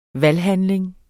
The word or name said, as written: valghandling